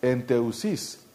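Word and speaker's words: enteuxis: Este es otro verbo que significa, que se pronuncia enteusis, venir y estar juntos, conversar y suplicar por algo específico.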